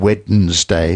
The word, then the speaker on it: Wednesday: And Wednesday, three syllables, Wednesday too. So